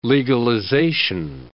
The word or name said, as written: legalization